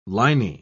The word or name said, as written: liny